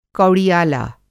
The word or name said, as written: कौड़ियाला